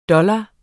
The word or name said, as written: dollar